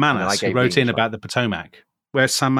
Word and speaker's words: Potomac: Potomac? – It's the Potomac.